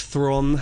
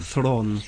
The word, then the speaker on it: Theron: Seth Rogen and Charlize Theron have a... – Theron. – Apparently the E is really sort of unpronounced. It's Theron. It's South African, isn't it? – Yeah.